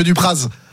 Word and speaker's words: Dupraz: Droit de réponse, monsieur Dupraz. – Ouais. Donc vous êtes pas savoyard vous par contre, ni au savoyard, parce que normalement on prononce pas le Z, mais c'est pas grave.